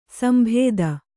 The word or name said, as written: ಸಂಬಾಧ